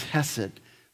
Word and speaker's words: hesed: And because she has a changed life, the evidence of that life is hesed. This is the most important word in all of the Old Testament. Sometimes we pronounce it chesed. Depends on your mood or your day, right? Hesed, chesed.